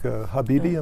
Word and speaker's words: Habibi: And you wrote a young adult book, Habibi, am I saying it correctly? – Yes, that's right.